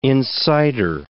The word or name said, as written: insider